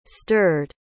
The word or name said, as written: stirred